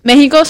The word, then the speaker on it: México: Aquí dice, México se escribe con X, no con J, por razones históricas de adaptación fonética del español. Antiguo respeto a la tradición americana y apego a la identidad local. Aunque hoy se pronuncie con el sonido de la J, la grafía original con X se mantuvo firmemente en América a lo largo de los siglos.